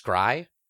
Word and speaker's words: scry: Oh, it is scry. – Okay, scry. All right.